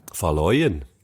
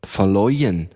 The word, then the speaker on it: Faloyin: Falloin. Ich weiss immer noch nicht, ob ich es richtig ausspreche. Falloin. Ich wie ist es geschrieben? – Keine Ahnung. Es ist geschrieben F-A-L-O-Y-I-N. – Falloin. – Falloin.